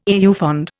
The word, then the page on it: au fond: Indian